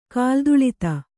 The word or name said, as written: ಕಾಲ್ತುಳಿತ